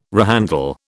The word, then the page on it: rehandle: British English